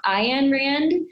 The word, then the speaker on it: Ayn Rand: Ayn Rand.